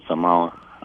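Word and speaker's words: Самарра: Я правильно назвал город Самара? – Да, это был город Самара.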